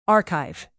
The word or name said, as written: archive